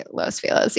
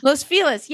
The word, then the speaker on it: Los Feliz: How do you say Los Feliz? Is that right? Am I saying it right? – I say Los Feliz, yeah. – Los Feliz, yeah.